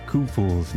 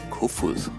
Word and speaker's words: Koefoed: So here comes a pronouncer for Susanne Kufuhl, which I'd like to point out is spelled K-O-E-F-O-E-D. – Kufuhl. Let me say that again. Susanne Kufuhl. – I do not hear the D in Kufuhl.